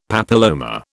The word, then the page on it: papilloma: British English